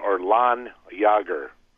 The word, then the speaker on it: Landjaeger: Well, he said it's pronounced, you don't pronounce the D or the J, so it's, and yeah, so it's Landjaeger. Landjaeger. – Landjaeger. – I guess. – Well, you still pronounce Landjaeger. – A J, you do it like a Y, like with one.